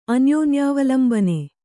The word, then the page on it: ಅನ್ಯೋನ್ಯಾವಲಂಬನೆ: kannada